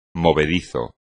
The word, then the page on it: movedizo: espagnol